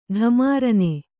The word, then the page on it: ಢಮಾರನೆ: Kannada